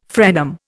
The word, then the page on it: frenum: Indian